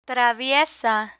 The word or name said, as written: traviesa